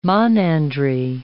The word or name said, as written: monandry